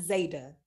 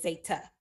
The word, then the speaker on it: Zeta: It's not Zeta. There's no D. It is Zeta, pronounce my T.